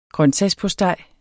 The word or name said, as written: grøntsagspostej